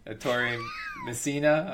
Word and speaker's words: Ettore Messina: I don't know how to say his name. Ettore Messina, I want to say. – Yeah, that's close enough. Yeah.